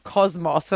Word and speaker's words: Cosmos: To be fair, I did think my producer was mispronouncing Cosmo, and I was like, you mean Cosmo? She's like, Cosmos. And I was like, I think that's a typo here, but you know what's more impressive? Cosmos.